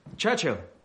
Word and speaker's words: Churchill: Churchill? That's how you say it. All together like that.